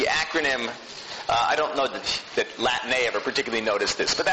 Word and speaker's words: Latané: It looks like Latane, but you pronounce it Latané, and the B stands for Bib.